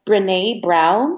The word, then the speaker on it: Brené Brown: And I think that's by Brené Brown.